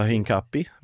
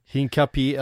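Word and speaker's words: Hincapié: Det pratas också om då Bayer Leverkusens, du får hjälpa mig med uttalet här på efternamnet. – Ja, Hinkapi. – Hinkapi, alltså det är ju en accent över et.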